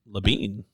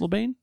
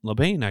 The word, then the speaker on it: Labine: It's Labine. That's what it looks like. Are you sure it's Labine? – Yeah, dude. I watched two seasons of Reaper. – Oh, do they say his name? – Yeah, it's his character's name, Tyler Labine.